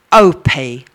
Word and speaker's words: Opie: It's Iona Opie, spelled O-P-I-E.